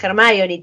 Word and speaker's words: Hermione: Porque los españoles pensaban que se pronunciaba Hermione y tipo JK más adelante dijo, che, no, se dice Hermione, tarado.